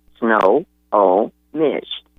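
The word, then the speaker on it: Snohomish: Good morning, and you have it wrong. There are three syllables there. – Okay. – S-N-O for snow, H-O for hoe, and M-I-S-H for Mish. Snow, oh, Mish.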